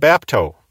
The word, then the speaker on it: bapto: First of all, the parent word, bapto, that I've mentioned multiple times. That is the basic verb, bapto, and that is spelled B-A-P-T-O. Related to bapto is the noun Baptisma. Spelled B-A-P-T-I-S-M-A, baptisma.